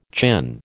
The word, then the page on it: chin: American English